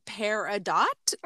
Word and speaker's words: peridot: I say peridot because I do it like French where the T is silent. – And I think I was saying peridot, right? – Yes, you were saying peridot. – So it's phonetically, it's peridot.